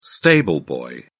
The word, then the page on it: stable boy: British English